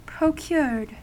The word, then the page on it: procured: American English